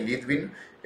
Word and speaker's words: Litvin: Tanto en Instagram como en Twitter me encuentran como Coqui Litvin.